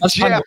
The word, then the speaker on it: Geoff: Jeff Calkins. It's spelled G-E-O-F-F.